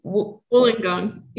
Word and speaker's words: Wollongong: I'm going to mispronounce it Wollongong. Wollongong, yeah. Wollongong, okay.